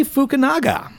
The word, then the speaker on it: Fukunaga: The long-in-development film version of Stephen King's horror epic It has landed a new director, Cary Fukunaga.